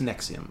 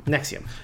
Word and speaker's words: NXIVM: It's pronounced Nexium. – Nexium.